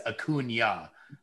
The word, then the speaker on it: Acuña: But in actuality, his name is Acuña. That would be the Spanish pronunciation of it.